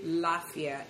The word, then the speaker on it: Lafia: And then In Hausa, they have something they say Lafia, L-A-F-I-A. So you should look at both. It's similar, just the A was taken out.